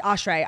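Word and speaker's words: Ashrei (אשרי): Like, yeah, except the way it's spelled in Hebrew, Olive Shid Reishush, Reishud, is pronounced Ashrei. Ashrei Yoshrei Veitecha, like the bracha. – No, but the prayer is Ashrei Yoshrei.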